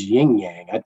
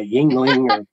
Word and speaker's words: Yuengling: what the heck is Ying Yang? I'm a Yuengling. No one could ever pronounce it right until they understand what it is.